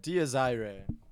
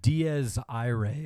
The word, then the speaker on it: Dies Irae: Dies Irae is how it's pronounced, yeah. – It's the Catholic Day of Reckoning in the Apocalypse. Dies Irae is how it's pronounced.